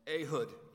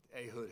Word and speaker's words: Ehud: Well, now it's the rise of this one man called Ehud, E-H-U-D.